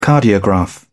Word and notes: cardiograph: British English

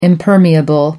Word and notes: impermeable: American English

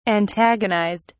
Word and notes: antagonized: British English

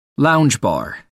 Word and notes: lounge bar: American English

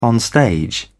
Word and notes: onstage: British English

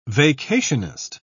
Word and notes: vacationist: British English

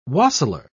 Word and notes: wassailer: British English

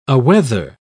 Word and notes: aweather: British English

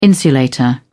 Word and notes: insulator: British English

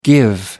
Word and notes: give out: American English

